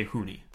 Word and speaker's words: Hunie: And you say Hooney. – Hooney. – Okay. – Honey. – And it's spelled, listeners, H-U-N-I-E.